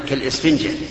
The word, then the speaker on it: إسفنجة: هذه الوصية لا تجعل قلبك كالسفنجة. أنا أحسبيها ألف أحسبيها همزة كالإسفنجة هكذا ننطق بها.